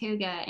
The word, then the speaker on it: hygge: The word is spelled H-Y-G-G-E, but it's pronounced Huga.